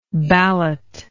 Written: balloter

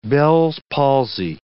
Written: Bell's palsy

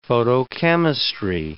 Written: photochemistry

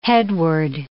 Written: headword

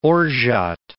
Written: orgeat

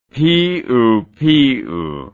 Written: piupiu

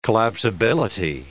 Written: collapsibility

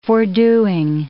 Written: fordoing

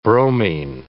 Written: bromine

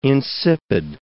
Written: insipid

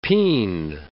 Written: peen

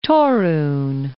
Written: Torun

